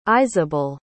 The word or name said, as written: iseabal